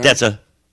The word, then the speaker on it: Kerstmarkt: That's the home of the holiday Kurtzmarkt. Kurtzmarkt, I believe they pronounce it. Okay. Kerstmarkt.